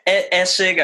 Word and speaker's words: Essig: Essig, I guess, like it's on the first syllable.